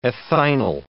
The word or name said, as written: ethinyl